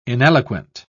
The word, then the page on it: ineloquent: British English